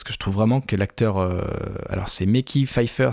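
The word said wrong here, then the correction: Mekhi Phifer: Euh, je crois que ça se prononce Mekai, Mekai Pfeiffer.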